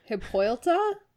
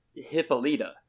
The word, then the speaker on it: Hippolyta: Hippoilta? – All right, yay. – Is that how you had pronounced it? – Maybe. – Okay. That's Hippolyta.